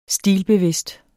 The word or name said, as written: stilbevidst